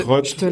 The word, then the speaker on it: Roth: Ça se prononce Roth et ça s'écrit, ça s'écrit R-O-T-H.